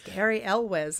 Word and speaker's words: Cary Elwes: It's still amazing to me that that's actually just how you pronounce his name. – Is it? – I believe it's just Cary Elwes. Like, people, it's just like phonetic.